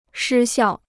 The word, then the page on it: 失效: shī xiào